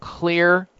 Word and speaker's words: Xlear: It's called Clear Nasal Spray or Kids Clear Nasal Spray. They have two, one for adults. The Clear Nasal Spray, it's spelled X-L-E-A-R. The X stands for xylitol, and then you have Kids Clear Spray.